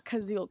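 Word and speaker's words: Cazielle: Kazeel Cakes.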